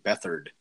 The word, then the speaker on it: Beathard: And as we're talking about pronunciations, I think it's actually CJ Beathard.